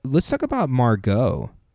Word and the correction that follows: Margot: I think that is not how you pronounce her name. – Yeah, you put a crazy emphasis on the Mar. – Margot? – Yeah, it's not. It's not. – How do you pronounce it? – Margot. – Oh, Margot.